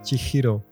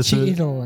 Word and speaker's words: Chihiro: m'avait dit Mais tu sais, Chihiro, ça se prononce Chihiro.